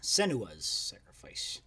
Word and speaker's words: Senua's Sacrifice: Other than Setsuna's Sacrifice. – Yeah. Senua's Sacrifice.